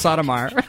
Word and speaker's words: Sotomayor: It's pronounced Sotomayor. That's what I pronounce it as.